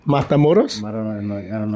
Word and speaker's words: Matamoros: It was in uh, Mata Matamoros. I don't know if I'm saying that right. M A T A M O R O S. Matamoros.